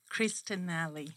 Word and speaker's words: Cristanelli: Cristanelli.